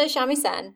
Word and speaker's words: shamisen: Wait, is that right? Is that how you say it? Yeah. He plays the shamisen, and his grandfather passes away, and at that point he decides to leave home and like search for his sound.